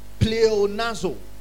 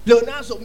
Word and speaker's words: pleonazo: Increase comes from the Greek word pleonazo. Pleonazo is spelled P-L-E-O-N-A-Z-O. P-L-E-O-N-A-Z-O.